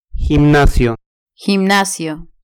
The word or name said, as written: gimnasio